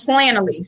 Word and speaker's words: Planoly: It's called Planoly. So it's P-L-A-N-O-L-Y.